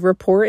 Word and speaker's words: rapport: And, you know, to be honest, I don't know what the true definition of rapport is. Why is there a silent T?